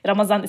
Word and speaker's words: Ramazan: Ramazan. Ramadan. Ramazan ist die türkische Aussprache.